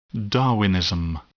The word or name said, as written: Darwinism